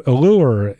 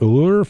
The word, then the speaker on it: Ellur: So we will return to Allure, E-L-L-U-R is how you spell it, Allure, from the CD At Home in My Mind.